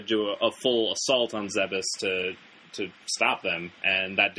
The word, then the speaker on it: Zebes: for the longest time, I thought it was Zebes up till... Once Other M came out, when Sakamoto actually, well, throughout the advertisement, the right pronunciation is Zebes.